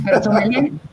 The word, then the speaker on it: sommelier: Es una sommelier.